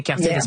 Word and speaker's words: Cartier: Yeah, Am I saying that right? Cartier, yeah. Spectacle.